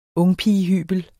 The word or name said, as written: ungpigehybel